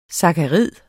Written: sakkarid

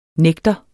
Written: nægter

